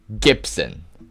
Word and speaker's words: Gipson: And that is spelled G I P S O N. First, uh, first time I've ever heard someone, uh, someone with the last name of Gibson with a P.